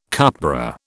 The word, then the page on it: cupbearer: British English